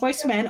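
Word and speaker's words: Boyz II Men: That is it's like Roman numeral two. – Yes. – You know, so it's pronounced Boys to Men, also known as B2M.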